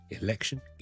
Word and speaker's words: election: get，let's get together，同埋 election，election，ele，听到个 a 先至 k，election，election。唔好因为呢个 syllable 短咗，因为 PTK 喺后面，一个 voiceless consonant 喺后面，而令到个 vowel quality 转咗。 OK，所以 a election get get，今日讲到呢度，拜拜。